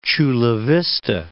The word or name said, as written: Chula Vista